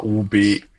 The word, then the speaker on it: Hubei: Ne, das ist Huibei. – Ah, okay, nee, das war's nicht. – Also Hubei und jetzt dürft ihr mich alle dissen dafür, dass ich das nicht aussprechen kann.